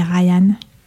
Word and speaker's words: Ryan: Alors, Ryan Kilgore s'écrit R-Y-A-N, plus loin, K-I-L-G-O-R-E.